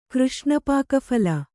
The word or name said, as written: ಕೃಷ್ಣಪಾಕಫಲ